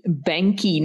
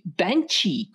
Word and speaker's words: Banchi: And this week I want to congratulate our cover artist, and I'll share it on the screen here as well, is Danny Banky. Now, or it could be that pronunciation, Val. – Mm-hmm. – B-A-N-C-H-I could be Banchi, couldn't it? – Mm-hmm. – But I asked Alessio. – Alessio. – Yes, my master of Italian. And I put that word in and he says... – Banchi. Banchi. – Banchi. – So should we go with the Italian pronunciation? – Sure, why not?